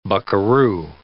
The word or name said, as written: buckaroo